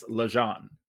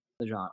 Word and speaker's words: LeGendre: For those of you who may not be familiar, it is spelled LeGendre. I have always heard it pronounced as LeJean. – LeJean, okay.